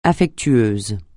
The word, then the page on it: affectueux: French